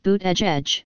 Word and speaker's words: Buttigieg: Buttigieg, pronounced Buttigieg, becomes the nation's first open LGBT candidate to make a run for the White House.